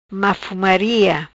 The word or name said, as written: Mafomaria